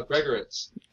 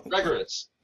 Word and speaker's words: Gregorits: Uh, Gregorits. – Gene Gregorits. Um, yeah. Well, Gregorits.